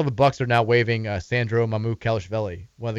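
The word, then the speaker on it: Sandro Mamukelashvili: Sandro Mamu Kalishvili.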